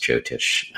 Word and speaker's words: Jyotish: And for anybody else that's interested in that, it's J-Y-O-T-I-S-H, pronounced Jyotish or Jyotish.